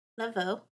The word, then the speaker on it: Laveau: All right, so we're going to talk about Marie Laveau. And it's French, so it's spelled with the E-A-U. – That's insane. – Whatever. We're in New Orleans, so E-A-U is an O.